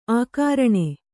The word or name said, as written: ಆಕರಣೆ